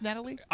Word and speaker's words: Nathalie: Is it still pronounced Natalie? I'm sure it is. No one goes by Nathalie she's from London though, so I don't know if it's a British thing or if it's like a like the favor versus favorite, you know, color color. – Like yeah, but it's pronounced the same in any case.